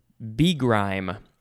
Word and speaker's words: begrime: Next we have begrime. Could also be pronounced begrime. But the emphasis is probably still on grime.